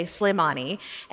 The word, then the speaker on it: Sulaymani: It was called Sulaymaniyah, or as the locals say, Sulaymani.